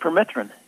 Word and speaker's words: permethrin: permethrin. Is it? Am I saying it right? – Permethrin, yeah.